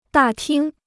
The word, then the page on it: 大厅: dà tīng